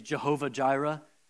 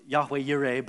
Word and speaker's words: Yahweh Yireh: And by the way, if you've ever heard that phrase, Jehovah Jireh, I had it as the title of the sermon. That's kind of the Hebrew a little bit. It's actually pronounced Yahweh Yireh, but that's fine. Jehovah Jireh is the phrase, the Lord will provide.